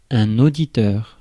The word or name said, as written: auditeur